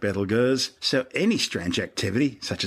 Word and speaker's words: Betelgeuse: It's currently referred to by astronomers as Betelgeuse, which has been commonly corrupted to Betelgeuse. Don't say it three times. Both names are actually tortured mispronunciations of its original Arabic name, Ibtalyaza, meaning the hand of the big man of course being Orion the Hunter.